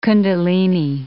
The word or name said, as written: kundalini